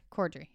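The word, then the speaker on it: Corddry: Dwayne The Rock Johnson, Rob, I can never say it right, Cordry? – Cordry, yeah.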